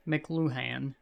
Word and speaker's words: McLuhan: This theory, proposed by Marshall McLuhan in the 1960s.